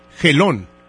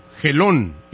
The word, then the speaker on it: Gelón: mi apellido es Gelón, con G de gato, Gelón y con acento en la O.